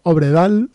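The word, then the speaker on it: Øvredal: Del año 2016, del director André, a ver cómo pronuncio esto, Obredal, que supongo que no Obredal. Se pronuncia así porque es noruego el amigo.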